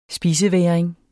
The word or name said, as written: spisevægring